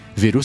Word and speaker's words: Veroo: E a promoção especial aqui para os ouvintes do ADT é o seguinte: se você for em veru.café ADT, Veru se escreve V-E-R-O-O, com dois Os mesmo.